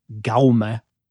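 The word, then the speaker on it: Jaume: But as far as I can tell, the J would be pronounced as a G. And so an approximation of it. So the first name would be like Gaume. – A hard G. – Yeah, like, so the English pronunciation would be like the G in rogue.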